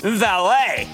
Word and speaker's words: Vale: I was looking at VALE. What do you think? – Valet, often mispronounced Vale.